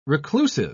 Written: reclusive

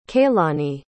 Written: kaylani

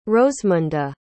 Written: rosemunda

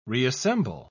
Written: reassemble

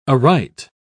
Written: aright

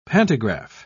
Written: pantograph

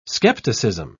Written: skepticism

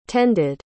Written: tended

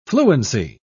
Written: fluency

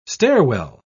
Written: stairwell

